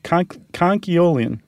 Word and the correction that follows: conchiolin: Yeah, you're throwing that L in there. – Mm. – It's very easy to want to do. – Conchiolian. – I get the urge. Yeah, conchiolin. And nacre, by the way, is spelled N-A-C-R-E.